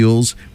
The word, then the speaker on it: Elwes: Cary Elwes.